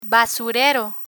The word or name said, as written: basurero